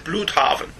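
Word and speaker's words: Blüdhaven: Is that how you say it? – I said Bloodhaven. – Bloodhaven. – Yes. because it has an umlaut over the U. – It's German. – Yes. – Right. – So I presume that's Blutharven.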